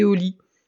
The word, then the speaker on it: Oli: On écoute aussi le soir, euh, bah, au moment d'aller se coucher, ça s'appelle tout bêtement Une histoire et au lit. Au lit, ça s'écrit O-L-I.